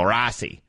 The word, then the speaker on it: Rossi: And just for clarification, you're supposed to say his name that way. – Okay. – Some people don't know that. It's either Rossi or you just say it like a upper Midwest go Rossi. Those are the two Rossi. – Those are the two ways he wants it.